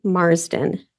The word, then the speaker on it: Marsden: My name is Lily Marsden, L-I-L-Y. M-A-R-S-D-E-N.